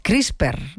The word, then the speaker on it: CRISPR: Todo lo que tiene que ver con esto de las CRISPR, ¿no? No sé si lo digo bien, ¿no? – Sí.